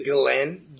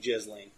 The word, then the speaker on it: Ghislaine: It's It's Glen? – Glen. – Glen. – Okay. It's how you would like, I guess, her, how she would pronounce it. Even though it looks like Jisling.